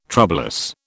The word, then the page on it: troublous: British English